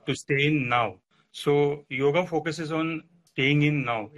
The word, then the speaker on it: yoga: So the word yoga itself, yoga in English is it's pronounced as yoga, but in our Indian culture, mm-hmm. – it is called as yoga.